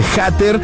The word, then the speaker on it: hater: El término hater, o bueno, se escribe H-A-T-E-R. Hater se pronuncia hater.